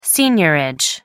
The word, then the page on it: seigniorage: American English